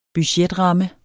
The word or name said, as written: budgetramme